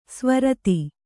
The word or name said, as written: ಸ್ವರಿತ